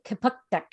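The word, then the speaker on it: Kjipuktuk: So Halifax, Nova Scotia is based in, it's Kapuktuk, which is the Mi'kma'ki word.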